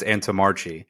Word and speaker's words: Antomarchi: The truth is, I say that it's Antomarchi. I have run into Italians that are like, that's a disgrace. You should never say it that way.